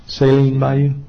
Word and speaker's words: Saline Bayou: Now you pronounce this S-A-L-I-N-E. How do you pronounce that? – Saline Bayou.